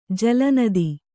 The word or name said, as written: ಜಲನದಿ